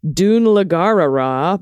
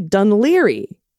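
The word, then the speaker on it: Dún Laoghaire: Oh, here, look, most North Americans pronounce it Dun Lagarara, but it's actually Dunleary. Oh, okay, so I was close. Rhymes with Sunbeery.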